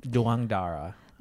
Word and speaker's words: Douangdara: Douangdara.